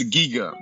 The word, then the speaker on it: Giga: It's Giga. You know, like Giga, gigabyte. Right? – Yeah, so yeah.